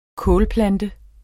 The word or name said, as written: kålplante